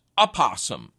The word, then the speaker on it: opossum: The name of the species that's native to the United States is spelled with that O on the front, but Webster says it's pronounced opossum, as if the O were a short A.